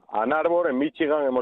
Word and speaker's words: Ann Arbor: Vamos a hablar del Real Madrid, que está, a ver si lo digo bien, si no ahora que me corrija el compañero Edu Pidal, en Ann Arbor, en Michigan. Edu Pidal, buenas noches. – Muy buenas, pronunciación perfecta.